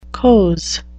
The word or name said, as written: coze